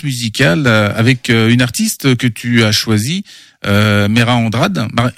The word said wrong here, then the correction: Mayra Andrade: Mayra Andrade. – Sur la prononciation, j'avais un doute.